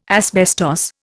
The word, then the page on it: asbestos: Indian